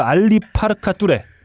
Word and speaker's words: 알리 파르카 뚜레: 아프리카 뮤지션이죠 알리 파르카 뚜레. 아, 네. 어, 이분 상당히 제대로 발음한 거 맞아요 아, 지금? 맞습니다.